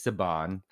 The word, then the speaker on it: Saban: Do you want to address how to pronounce Saban films, or is it Saban? – It's Saban. – Okay, but you know, being where I'm from, I grew up with a family whose last name was Saban, spelled the same way, pronounced that way.